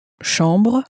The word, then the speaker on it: chambre: Chambre. – You'll notice also that the ch is pronounced sh in French, as you may have already said.